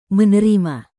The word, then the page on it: menerima: indonesian